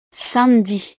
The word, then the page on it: samedi: frances